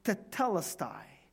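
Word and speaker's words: tetelestai (τετέλεσται): See, it's tetelestai. It is done. That's a Greek word, tetelestai. Can you pronounce it with me? It's pretty hard, huh? Tetelestai. So just, you have to really say tetelestai. Tai.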